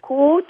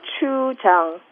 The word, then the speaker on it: gochujang: Gochujang. gochujang.